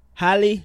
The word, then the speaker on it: Halle: Halle, that's how you say her name, right? – Mm-hmm.